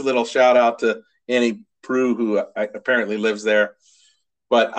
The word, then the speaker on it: Proulx: And that's Annie Proulx, or Proulx, actually, I heard is the correct pronunciation.